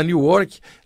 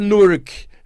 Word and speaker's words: Newark: Inclusive, a gente, o brasileiro fala Newark, lá tem uma comunidade de brasileiros enorme. Um abraço aí para vocês que estiverem escutando o programa. E aprendi que a pronúncia certa é Newark, né, como o pessoal chama lá.